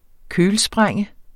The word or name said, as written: kølsprænge